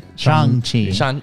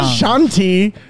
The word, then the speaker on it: Shang-Chi: Because it's Shang-Chi? – Shang-Chi. – Not Shanti. – Not a Shanti.